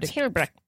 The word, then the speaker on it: Hillebrecht: Hard Hillebrecht. – At the end of that. – Yeah, I forget about German pronunciation.